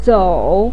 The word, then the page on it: zou: 3